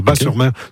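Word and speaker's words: Batz-sur-Mer: Oui, Bat, on prononce Bas, le T ne se prononce pas, Bas-sur-Mer.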